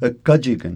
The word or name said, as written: kkajikn